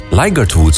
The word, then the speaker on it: Ligertwood: Schotse zanger, gitarist, Ligertwood, zo spreek je het uit.